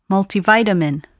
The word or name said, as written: multivitamin